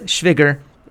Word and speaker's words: Schwigger: but I just want to tell you that the word Schwigger rhymes with trigger.